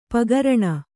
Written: ಪಗರಣ

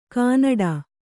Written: ಗಿಣ್ಣಿಡು

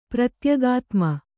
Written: ಪ್ರತ್ಯಗಾತ್ಮ

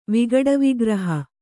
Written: ವಿಗಡವಿಗ್ರಹ

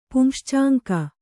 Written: ಪುಂಶ್ಚಾಂಕ

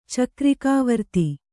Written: ಚಕ್ರಿಕಾವರ್ತಿ

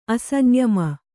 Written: ಅಸಂಯಮಿ

